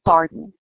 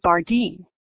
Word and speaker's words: Bardeen: And I always put that emphasis on een because people will call me Barden. Emily, Emily Bardeen. So they've got the Emily, they've heard it twice, and then they do the Bardeen.